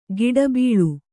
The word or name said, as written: ಗಿಡಬೀಳು